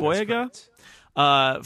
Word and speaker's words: John Boyega: John Boyega, is that right? – Mm-hmm. – Boyega, from Attack the Block.